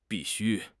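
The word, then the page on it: bi4 xu1: tone 4; tone 1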